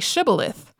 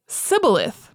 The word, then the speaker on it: shibboleth: If he said sibboleth, because he could not pronounce the word correctly, they seized him and killed him at the fords of the Jordan. 42,000 Ephraimites were killed at that time." So the word shibboleth was being used to distinguish the Gileadites from the Ephraimites, because Ephraimites couldn't pronounce the sh sound in shibboleth.